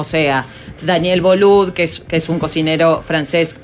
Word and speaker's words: Boulud: Sí, se dice en francés. O sea que yo lo dije mal, capaz. – No. – Pero se escribe B larga O-U-L-O-U-D. – Boulude. Boulude. – Pero da. – Boulud. – Eso, exactamente, gracias, Apo.